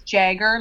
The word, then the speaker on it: Jagger: You know, I've had to grow up with a fair number of them, but most people mispronounce it. They think it's Jagger, like Jagermeister. – Or Jager Bomb. – Yeah, or Jager Bomb. Well, I remember one time I said to someone, it's Jagger like Mick, and they thought that was my whole last name, like Jagger like Mick.